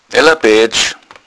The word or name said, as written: aeller